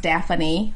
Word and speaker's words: Daffany: Unlike your character name, it's Daffany. It's actually pronounced Daffany.